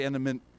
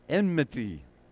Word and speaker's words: enmity: How do you say it? Enmity. I've been working on it for weeks, and I still can't get it. Can you say it? Enmity.